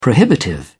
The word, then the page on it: prohibitive: British English